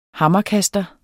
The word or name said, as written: hammerkaster